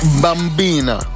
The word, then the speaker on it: bambina: Bambina.